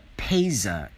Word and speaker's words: PASIA: And that is, you can tell what is called the PASA. It is spelled P like Peter and then Asia, A-S-I-A.